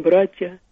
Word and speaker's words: братья: Очень часто йод не произносится. Ну, например, братья, говорим братья.